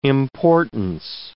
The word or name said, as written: importance